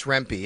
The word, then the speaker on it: Rempe: I believe it's Rempe.